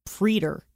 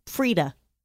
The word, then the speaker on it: praetor: I think it's pronounced Preeter. – Really? – In American. Preeta.